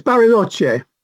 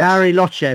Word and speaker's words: Bariloche: You are a freelance writer and you're based in Manchester and Bariloche, and I hope I said that right, in Argentina. – It's Bariloche. Yes. – Bariloche, of course it is.